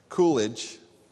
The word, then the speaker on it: Coolidge: Coolidge, I've been adding an R in there.